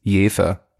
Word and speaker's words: Jever: Das heißt eigentlich nicht Jever, das heißt Jever, aber du mit deiner komischen schwäbischen Aussprache, du darfst von mir aus auch Jever sagen. Stimmt gar nicht, sage ich da. Dieses Bier heißt tatsächlich und im Ernst Jever, weiß aber niemand.